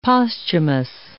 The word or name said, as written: posthumous